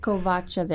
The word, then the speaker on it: Kovacevic: Kovacevic, okay.